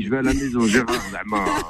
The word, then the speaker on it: Gérardmer: Gérardmer.